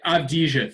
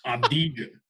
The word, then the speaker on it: Avdija: Just say Avdija. Think of it like you're Brad Pitt and Inglourious Bastards and just say it phonetically and it works. Avdija. – Avdija.